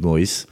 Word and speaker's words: Maurice: Et non, je n'ai pas dû faire d'un procès pour les cigarettes Philippe Maurice, puisque c'est mon nom et prénom, donc là-dessus, aucun souci. – Et oui, et Maurice, hein, ça s'écrit bien comme le prénom français, M-A-U-R-I-C-E, hein, et pas M-O-2-R-I-S.